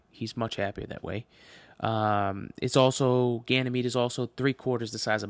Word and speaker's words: Ganymede: And now on to Ganymede, and I did look up the pronunciation this time beforehand.